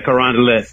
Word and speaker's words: Carondelet: In fact, he said, just say Carondelet Street. Is that how you say it, man? – Carondelet. Yeah.